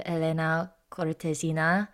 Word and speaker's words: Elena Cortesina: the case of Elena Cortesina, Flor de España.